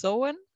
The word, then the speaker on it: Samhain: Ähm, für die, die das jetzt ein bisschen Probleme mit dem Begriff haben, ja, also geschrieben heißt es Samhain aber ausgesprochen wird es Sowen.